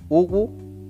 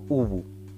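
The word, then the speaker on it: uwu: No obstante, si quisiera pronunciarlo como si fuera una palabra, por su grafía le, le correspondería la, la pronunciación Ubu o Ubu.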